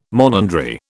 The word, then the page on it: monandry: British English